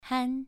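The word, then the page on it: han: tone 2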